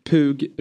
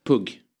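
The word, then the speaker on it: Pugh: Pug Rogefeldt. – Där måste jag rätta uttalet faktiskt. – Ja. – Pug. – Sa jag Pug? – Pug, sa du.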